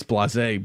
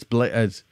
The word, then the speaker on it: Blaise: I want to say it's Blase, but it's Blaise, B-L-A-I-S-E, Pascal.